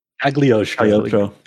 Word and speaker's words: Cagliostro: You're obligated to cover Caligostro's secrets. How do you pronounce that? – Cagliostro. – I can't speak Italian. – Like silent.